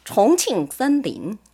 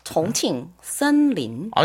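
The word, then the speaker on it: 정칭 산린 (重庆森林): 요 발음을 저는 좀 그 본토 발음을 한번 듣고 싶은데요. – 그러세요? – 정칭 산린. – 완전 틀리네 느낌이. – 비슷하지 않아요? 정칭 산린.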